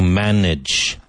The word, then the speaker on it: manage: To manage. To manage. To manage. Manage. Now, M-A-N-A-G-E, but manage.